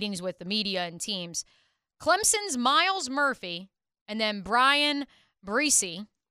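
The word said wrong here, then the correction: Bresee: Brise. – I'm going to keep mispronouncing it.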